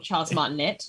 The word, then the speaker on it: Charles Martinet: even though Zach doesn't know how to pronounce Charles Martinet's name properly. But anyway. – What is he called? Charles Martinet? – Yep.